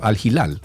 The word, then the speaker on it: Al Hilal: En fútbol, Neymar es el delantero del Al Hilal. ¿Cómo se dice eso? Al Hilal, así mismo.